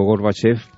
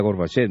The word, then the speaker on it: Gorbachev: Digo Gorbachev porque en ruso se pronuncia Gorbachev, dirá alguien, es que Chávez ruso, bueno, sé algo.